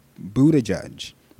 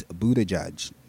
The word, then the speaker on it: Buttigieg: Seriously, y'all, Buttigieg, that is not hard. All those people that cannot say his name, including the political pundits on TV, seriously, get it together. It's Buttigieg, not difficult.